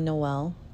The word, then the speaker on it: Noel: So, my first name, Noel.